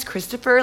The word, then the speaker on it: Christopher: And my last name is Christopher, like Christopher Columbus.